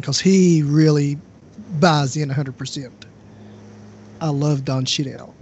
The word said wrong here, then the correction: Cheadle: I think you're talking about Don Cheadle. Is that who we're talking about? – Don Cheadle, yeah.